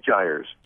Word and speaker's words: Gyres: Gyres. – Five Gyres.